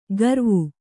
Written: ಕರಾವು

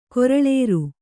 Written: ಕರುಳಿರಿ

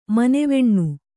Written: ಮನೆವೆಣ್ಣು

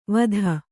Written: ವತ್ತಾ

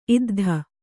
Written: ಇದ್ಧ

